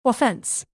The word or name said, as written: offense